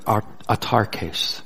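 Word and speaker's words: autarkes (αὐτάρκης): The Greek word here translated content is the word autarkes.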